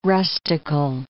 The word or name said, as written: rustical